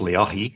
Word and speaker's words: Leahi: I know, like, when I was there, we walked up Diamond Head, which I guess is, I think it's Lehigh, or is that the correct pronunciation? – Well, that's the Hawaiian word. It's Leahi, and that's Diamond Head.